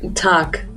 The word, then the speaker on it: Tag: Tag. – With a K sound? – No, G, tag. – It is a G. Oh, so it is Guten Tag, like that.